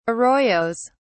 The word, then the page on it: arroyos: British English